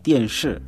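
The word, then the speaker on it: dianshi (电视): Televisheni tunasema ni dianshi. Dianshi.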